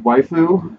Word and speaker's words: waifu: It's pronounced waifu.